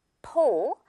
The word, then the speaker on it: pole: P-O-L-E. 이건요, 우리 pole이죠. 이것도 은근히 pole이라고 하는 친구들 있는 것 같아요. 요거는 오 발음 해주면 돼요. pole, 어떤 대, 봉 같은 거 말하죠.